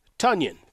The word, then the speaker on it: Tonyan: Uh, as much as I'm not a huge Cole Kmet guy, you do bring in Tonyan don't forget, it right.